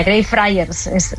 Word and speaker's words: Greyfriars: Así se pronuncia, Greyfriars.